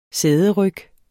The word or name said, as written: sæderyg